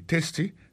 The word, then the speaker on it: tasty: Food, F-O-O-D, ça veut dire manger. Tasty, T-A-S-T-Y, ça veut dire goût. Goût dans bouche. T-A-S-T-Y, vous prononcez le testé, ça veut dire goût dans bouche.